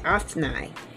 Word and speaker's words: Othni: The sons of Shimna, Othni and, Othni, that's a long I, Othni,